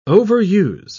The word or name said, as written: overuse